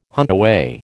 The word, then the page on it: huntaway: American English